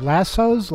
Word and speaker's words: lasso: Lassos? Lassos? How do you say that word? You're from out west. You must know. – Lasso. – Lasso? Like lassie. Lasso.